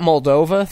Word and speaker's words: Moldova: Am I saying that right? – Mold that around your mouth and spit it out. – I know, it's disgusting. Moldavia. Fuck. Where is someone in the chat? Moldova. Thank you, Red Fox.